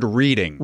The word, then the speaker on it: Reading: Well, let's go back for a second, because Woburn is spelled W-O-B-U-R-N. – Yes. And Reading is spelled like the word reading. – Right. But it's Reading, like Reading, England.